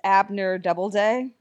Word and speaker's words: Abner Doubleday: There's a proboscis monkey named Paul, who is Abner Doubleday. – Yes. – Did I pronounce that correctly? – Yes. Yeah, you're good.